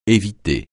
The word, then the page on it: éviter: français